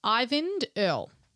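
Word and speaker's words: Eyvind Earle: So she handed the reins over to, and I want to get his name right, it's Ivind Earl. It's just spelt very strangely.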